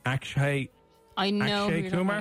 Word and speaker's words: Akshay Kumar: Akshay Kumar. Am I pronouncing that name? Probably not. – Akshay Kumar.